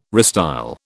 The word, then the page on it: restyle: British English